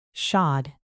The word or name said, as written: shod